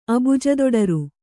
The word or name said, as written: ಅಬುಜದೊಡರು